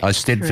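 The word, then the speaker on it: Eisteddfod: It's a, from memory, I think we talked about this last year, Pauline, it's a Welsh word. So you've got to make sure you spell it EISTEDFOD. Have I got that right? – Correct. – I Stedford. – Correct.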